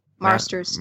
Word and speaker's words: Marsters: I don't know how you say his name. Is that how you say his name? Marsters. Marsters. Yes. Okay.